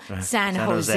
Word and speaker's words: San Jose: Like San Jose, no, San Jose. – 사로제 It's a J, but it's a 허.